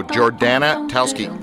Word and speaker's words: Jordana Talsky: Jordana Talsky. That's how you say her name.